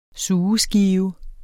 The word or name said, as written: sugeskive